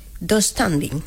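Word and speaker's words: döstädning: Dos standing. – Sí, así. Sí, tengo esa silla del IKEA, sí, se pronuncia así.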